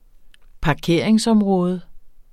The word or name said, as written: parkeringsområde